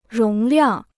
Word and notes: 容量: róng liàng

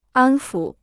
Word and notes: 安抚: ān fǔ

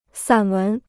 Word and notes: 散文: sǎn wén

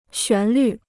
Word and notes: 旋律: xuán lǜ

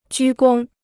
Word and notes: 鞠躬: jū gōng

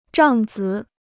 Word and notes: 帐子: zhàng zi

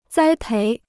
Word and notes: 栽培: zāi péi

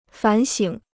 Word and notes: 反省: fǎn xǐng

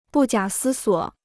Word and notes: 不假思索: bù jiǎ sī suǒ

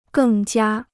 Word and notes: 更加: gèng jiā

